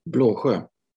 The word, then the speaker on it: Bloge: And I'm going to apologize in advance for maybe probably mispronouncing your last name. Is it Blesje? – Bloge. – Oh, that's very different. Blogo. Don't worry about it. – something like that.